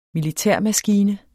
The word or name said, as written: militærmaskine